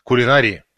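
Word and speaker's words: кулинария: Кулинарии. Ударение на «а», на третий слог. 621-й и прочее. Современные словари предполагают вариативное ударение, то есть правильным будет произношение и с ударной гласной «а», и с ударной гласной «и».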